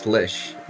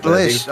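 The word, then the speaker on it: Slisz: Sills? – I think it's pronounced Slish, which doesn't look like it. – Slish.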